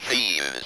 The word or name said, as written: thieves'